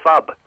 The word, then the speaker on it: phub: And it's fub. P-H-U-B. – Spell it again? – Yeah, look, it sounds a bit rude. I should spell it out. P-H-U-B.